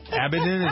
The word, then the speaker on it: Abedin: Abedin, is that how you pronounce it? – Yes.